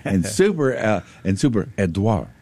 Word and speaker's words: Edouard: It is Hurricane Edouard, spelled E-D-O-U-A-R-D, not Eduardo. – Mm-hmm. – It is pronounced Edouard, French, Edouard.